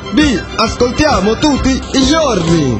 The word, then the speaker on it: giorni: Giorni se pronuncia giorni, me dice Ande, me dice Fergus.